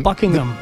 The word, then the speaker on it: Buckingham: Y de hecho, en inglés perfecto, el H medio que se es medio silencio. Buckingham. – Más o menos. – No se pronuncia mucho.